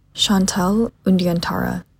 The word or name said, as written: chantale undyantara